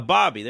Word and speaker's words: Ababiy: Um, how do you pronounce this? Is it a, a baby, a BB, a, bo- a, bo- a Bobby? There you go, Nikita Bobby.